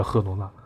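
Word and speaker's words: Girona: 第十名的赫罗纳，这个就或者叫吉罗纳。我记得我播的时候，然后这个本地人他喊的是吉罗纳，然后呢，吉罗纳应该是英语的念法。如果你按他的用英语念法叫吉罗纳，那可能用西班牙语念法可能叫赫罗纳。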